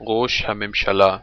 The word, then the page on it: prime minister: Hebrew